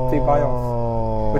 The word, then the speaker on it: Ceballos: Well, if you want to say it in Spanish properly, I know Spanish, all the C's and S's are thuz. – Oh. – So it's Ceballos.